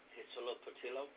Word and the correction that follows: Huitzilopochtli: you butchered that, Boo. Huitzilopochtli. Huitzilopochtli. Pochtli.